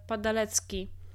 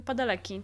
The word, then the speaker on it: Padalecki: E, to on sam, e, kiedyś słyszałam, jak wymawia swoje nazwisko i on mówi Padaleki. Nie Padalecki, tylko Padaleki.